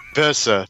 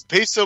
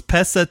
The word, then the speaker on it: Pesa: Pesa Tupa. Okay, there we go. – So yeah, it's Peso, but with an A, so Pesa. – So it's Pesa Tupa, offensive tackle out of College of San Mateo.